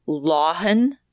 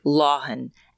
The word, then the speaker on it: Lauhon: His name was Ellis Lauhan. And I don't know that I'm saying that right, but it's spelled L-A-U-H-O-N, Lauhan.